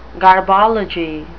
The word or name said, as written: garbology